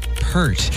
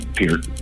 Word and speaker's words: Peart: It's pronounced Peart, by the way, too. Not Burton. – Did you see the part in I Love You, Man, where he corrects the, I've had people call me on it. I'm like, oh no, I have the audio to prove it. It is pronounced Peart, not Pert.